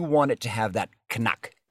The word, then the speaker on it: Knack: K-N-A, this is not a knack joke, but K-N-A-C-K. Oy. – K-N-A-C-K, knack. No. – But it has the word itself, because they pronounce the K, it's not a silent K, it has a click sound to it, right? Knack.